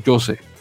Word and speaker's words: José: José Madeira, que está con Grenoble. José, bueno, Jose, se pronunciaría.